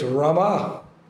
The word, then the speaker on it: Rama: It's Rama. Krishna's name is Rama. That's the proper pronunciation of the Mahamantra, the Yuga Dharma, the process for this age."